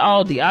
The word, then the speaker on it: Aldi: Number three, shout out to Aldi. I call it Aldi. Some of you might call it Aldi. It's spelled A-L-D-I.